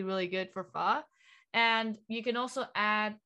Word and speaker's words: pho: And like they commonly eat, is it pho? Did I pronounce it correctly? And like, yeah, it does seem like Vietnamese food consists of like very comforting dishes. So like, and they always look good. I always see them and like everywhere, they always look good and like very comforting to eat. Yeah. – Yeah, so that's your pronunciation is actually pretty good.